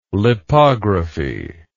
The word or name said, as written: lipography